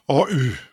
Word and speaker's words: Oy: Und ich habe mich gefragt, wie Ihr Planungsbüro richtig ausgesprochen wird. Lundberg Oy oder Oy? – Oy, das ist... – Oy. – Ja.